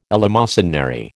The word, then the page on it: eleemosynary: American English